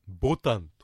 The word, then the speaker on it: ボタン: はい、本当はね、共通語だとボタンって言うんですけどもね、新潟の方はボタンという風にアクセントをつけるといいと思います。